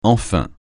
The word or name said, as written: enfin